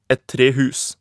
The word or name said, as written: et trehus